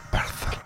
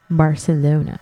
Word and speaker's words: Barcelona: Barcelona. – Bitch, say it right. Barcelona. – Barcelona. Oh, sorry. I'm so sorry.